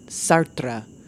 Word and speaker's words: Sartre: That is the correct pronunciation, Sartre. I personally like to say Sartre, Jean-Paul Sartre. That's just a little more my style.